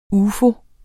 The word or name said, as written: UFOER